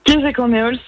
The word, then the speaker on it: Kézeg en éol: Vous reprenez tout de suite la prononciation, je rougis de honte, je vous en supplie. – Kézeg en éol, c'était très bien prononcé, je n'ai rien à dire.